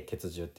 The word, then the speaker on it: 結集: で、本来でしたら今回の研修会もあの血獣って呼んでるんですよ。冬季、冬の冬季血獣って言ってて、血獣って何ぞやっていうと、あの結集って書いて、あのみんなで集まるっていう結集って書いて結集って読むんですけれども、